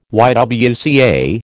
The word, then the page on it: YWCA: American English